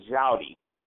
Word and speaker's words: Joddy: I mean, the actual pronunciation of my first name is Joddy.